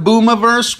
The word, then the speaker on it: Boomiverse: Now, he named this one Boomiverse, which rhymes with universe, but he's not talking about space on any of these tunes as far as I'm concerned.